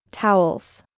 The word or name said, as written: Towels